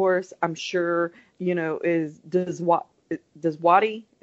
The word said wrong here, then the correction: Djawadi: is that how you say it? Djawadi. Djawadi, thank you.